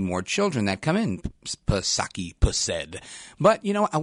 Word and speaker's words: Psaki: Speaking of reporters at the White House on Friday, Jen Psaki, she acknowledged, and you know her, Jen Pacircle Back Psaki, silent P.